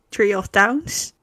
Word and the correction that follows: Trio of Towns: Zeg ik het goed? – Tree of Towns. – Hoe? – Trio of Towns.